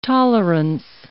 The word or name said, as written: tolerance